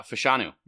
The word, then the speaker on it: Fashanu: Fashanu. Is that how you say it? – Fashanu? Oh. Oh, yeah, yeah, yeah. Fashanu.